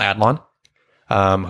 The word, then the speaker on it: Adlon: who we've mentioned is voiced by Pamela Adlon, whose name we mispronounced a lot in the last time we mentioned her. – We kept saying Adlon. – Yeah, we added a syllable.